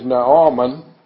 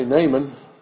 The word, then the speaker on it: Naaman: The proper pronunciation of his name is Naaman. You probably would rather I'd say Naaman. That's our English way of saying it.